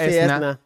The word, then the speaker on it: Esuna: De una A, Esuna, que se pronunciaba Esna, Esna.